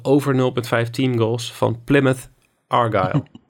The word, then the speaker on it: Plymouth: kunnen we nog één keer zeggen? Plymouth. – Plymouth? Plymouth. Plymouth. Oké. – Ja, of ik weet niet hoe je het, Hoe spreek je dit uit? Ja, volgens mij zeg je het fantastisch goed.